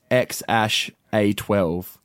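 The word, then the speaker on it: X Æ A-12: So how you say it is X-A12. Apparently, X stands for like unknown. – Mm-hmm. – The AE, which is Ash, is Elvish for AI.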